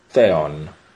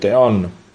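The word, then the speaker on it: theon (θεόν): Kai, o logos, en pros ton theon. Se non riuscite a pronunciare la TH come all'inglese, come l'ho pronunciata io, la pronunciate theon, non succede nulla.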